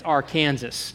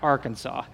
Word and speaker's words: Arkansas: It's El Dorado, and it's Arkansas, not Arkansas. But